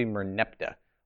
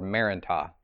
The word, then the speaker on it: Merneptah: The other correction I have from last week is I kept saying Merneptah Stele, which I did not realize I said that. It's supposed to be Merneptah or Merentah. Those are alternate pronunciations.